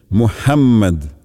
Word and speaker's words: Мухаммад: А в имени пророка Мухаммад следует обратить внимание на букву «х», которая произносится мягко, с придыханием, а также на то, что буква «м» в имени пророка произносится с удвоением «Мухаммад».